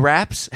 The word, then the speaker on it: Rapace: Noomi Rapace. Is that how you say it? – Yeah. Noomi Raps.